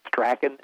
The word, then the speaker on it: Strachan: It's spelled Strachan, S-T-R-A-C-H-A-N, but he calls it Strachan.